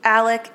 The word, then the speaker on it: Alex: So the theories are either boat crash victims, Stephen Smith, somebody associated with Stephen Smith, or Alex. – Yes, or the dad. – And how old is Alex? – Alec, it's Alec. Alec. I mean, it's spelled Alex, but it's pronounced Alec.